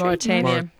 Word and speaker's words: Mauritania: Mauritania.